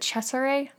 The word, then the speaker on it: Cesare: I think I made a note of how, like, during the scene where Dr. Caligari wakes up, did you pronounce it Cesare? – That's how I say it. I'm assuming it's supposed to be Italian.